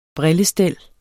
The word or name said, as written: brillestel